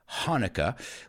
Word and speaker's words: Hanukkah: Those are Jewish people, Jewish friends of mine who celebrate Hanukkah, aunque tenga una CH, se pronuncia Hanukkah, which is an eight day celebration.